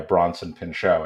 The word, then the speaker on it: Bronson Pinchot: And essentially they then, but one of them, one of the guys on this plane, Craig Toomey, who's beautifully portrayed by Bronson Pinchot, I think is how you pronounce it. Yeah.